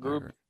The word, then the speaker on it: Grube: Coach, am I pronouncing that? It is Grube, right? I'm pronouncing that right. – Yep. Okay. Yep. You're right. Grube.